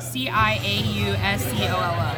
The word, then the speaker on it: Ciauscolo: Ciauscolo. – Can you spell it? C I A U S C O L O.